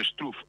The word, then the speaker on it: Struff: Straff è numero 74, lo pronuncio così, qualcuno potrebbe dire Struff, io dico Straff.